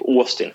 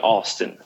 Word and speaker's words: Austin: Eller nu uttalar jag så svensk, när jag snackar med en svensk liksom, i Sverige säger vi typ Austin. De säger inte å där. Vi uttalar nästan som att stavas med ett å. Det är med Austin.